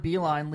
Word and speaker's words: Beilein: So, other hire is John Beilein leaving Michigan to go to Cleveland.